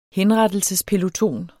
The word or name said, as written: henrettelsespeloton